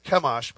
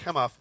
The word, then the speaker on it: Chemosh: It looks like Chemosh, but it's pronounced Chemosh.